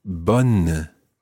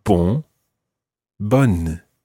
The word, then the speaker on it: bonne: However, when we come to say good night, we use a slightly different version of the word for good, and that is bonne. – Bonne. – And that's spelled B-O-N-N-E, as opposed to bon, which is spelled B-O-N. Listen to the difference between these two words. Bon, bonne.